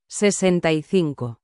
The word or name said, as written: 65